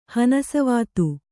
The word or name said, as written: ಹನಸವಾತು